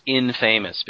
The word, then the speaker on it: infamous: I think it's pronounced infamous because it's more than famous. Infamous.